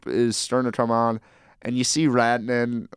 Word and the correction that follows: Ranta: what is it, Ranta? – Sampo Ranta, yep.